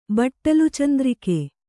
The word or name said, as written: ಬಟ್ಟಲುಚಂದ್ರಿಕೆ